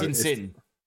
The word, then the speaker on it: Insigne: Do you pronounce the G in their names? So it's just Insigne. – No. Insage.